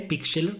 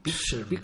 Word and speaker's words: Pixlr: Y luego hay otra que es un clásico, que es Pixlr, que es P-I-X-L-R, Pixlr, ¿cómo se pronunciará? – Pixlr.